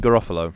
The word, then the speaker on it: Garofalo: Garofalo? – Yeah, Janine Garofalo.